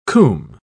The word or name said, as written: combe